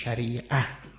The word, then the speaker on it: شریعه: اونجا وقف می کنید می گید شریعه، تا رو چنین تلفظ می کنیم، این میشه شریعه، یعنی راه رسیدن به آب.